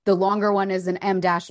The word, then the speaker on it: em dash: It's actually called an M dash and a Molly dash. – It's spelled EM dash weirdly.